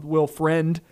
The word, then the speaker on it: Friend: Will Friend. I believe it's spelt like friend.